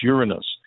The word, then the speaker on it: Uranus: The planet that we say professionally and pronounce it properly is Uranus.